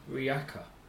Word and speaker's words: Rijeka: So this one's called Stadion Riyaka, Chris. – Where? – In Riyaka. – Riyaka. – Riyaka. – Oh, where's Riyaka? – Can you spell it? – R-I-J-E-K-A. – Is it Iceland? – Incorrect. – Riyaka. – Is it Handover? – Yeah. – Sweden? – Incorrect, it's Croatia.